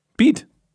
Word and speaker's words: Beat: Aber man spricht ihn, glaube ich, französisch aus. Wie spricht man den französisch aus? Weißt du nicht? Nein, Beat.